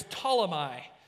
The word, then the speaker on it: Ptolemy: It says, the next stop after leaving Tyre was Ptolemy. That's right. That's a silent P there. Yeah, that's right. I watched the National Spelling Bee a couple weeks ago. Anyways, Ptolemy, where we greeted the brothers and sisters and stayed for one day.